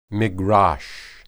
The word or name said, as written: migrash